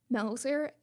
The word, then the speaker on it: Melzer: So my name is Elana, E-L-A-N-A dot Melzer, and that's spelled M as in Mary, E-L, Z as in zebra, E-R.